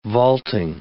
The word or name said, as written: vaulting